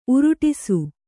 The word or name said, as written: ಉರುಡಿಸು